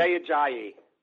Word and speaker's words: Jay Ajayi: Jay Ajayi. – Now you just said it right. Yesterday you were saying it wrong.